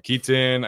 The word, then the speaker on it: Kitan: Um, Kitan, I think it's how you say it, Oladapo, and then Zion Tupuola-Fetui.